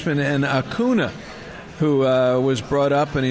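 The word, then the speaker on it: Acuña: And it's pronounced Acuna now, I just learned recently. There's an Enye in there, Ronald Acuna.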